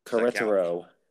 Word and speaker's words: Querétaro: Am I pronouncing that correctly? Is it Querétaro? – Querétaro. – Querétaro, sorry. Querétaro.